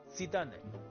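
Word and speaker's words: Zidane: 法国人告诉我，齐达内在法语的发音是什么？鸡蛋内，就是鸡蛋。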